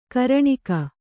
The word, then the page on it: ಘೂರ್ಣಕ: ghūrṇaka